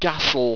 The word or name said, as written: gastl